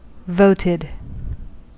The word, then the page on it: voted: American English